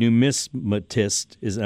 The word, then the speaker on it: numismatist: You are an affirmed numismatist.